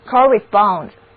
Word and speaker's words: correspond: 接下来的单词拼写为 C-O-R-R-E-S-P-O-N-D， correspond， correspond，两个字母 O 都读作 O。 C-O-R 这个前缀其实是 C-O-N 这个前缀的变体，意思是相互， respond 则是回答的意思，所以相互间有问有答就出现在这个书信当中，因此呢 correspond 的意思就是通信，是一个名词。